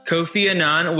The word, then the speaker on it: Kofi Annan: One of his favorite quotes is from, am I saying this right, Kofi Annan? – I believe so. – Kofi Annan, which states, knowledge is power, information is liberating, education is the premise of progress, in every society, in every family.